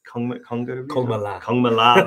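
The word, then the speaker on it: Kongma La: It's also got Chola, Gokyo Ri, Konga Ri, and I think I pronounced that wrong. Kongmala, that's it.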